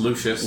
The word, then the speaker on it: Lucius: How do you say Lucius? – I think it's just Lucius.